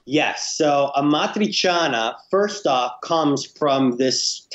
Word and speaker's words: amatriciana: How do you pronounce it, first of all? – Yes, I actually already said it. It's called amatriciana. – Amatriciana. Very good.